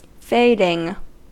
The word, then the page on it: fading: American English